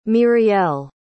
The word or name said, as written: mirielle